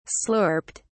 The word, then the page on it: slurped: British English